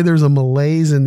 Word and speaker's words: malaise: It's from the French malaise. Two syllables, M-A-L-A-I-S-E.